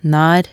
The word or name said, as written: naer